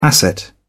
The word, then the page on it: asset: British English